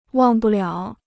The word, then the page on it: 忘不了: wàng bù liǎo